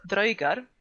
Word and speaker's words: draugar: Och ja, det uttalas dröjgar och dröjgur. Okej.